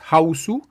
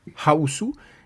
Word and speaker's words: Hausu: House, el nombre en Japón, yo no sé pronunciar Japón, pero es Hausu, Hausu, y ahí se van a divertir, pero, o sea, como, nunca lo han pasado y espero que nos cuenten.